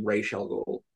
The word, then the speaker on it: Ra's al Ghul: We do have to pronounce his name Ra's al Ghul.